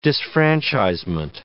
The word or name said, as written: disfranchisement